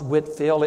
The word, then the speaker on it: Whitefield: It's pronounced Whitfield, it's spelled Whitefield, a great British preacher from long ago.